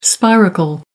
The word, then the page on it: spiracle: American English